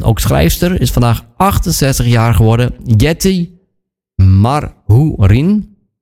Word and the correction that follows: Mathurin: Mathurin, zo moet ik hem volgens mij uitspreken.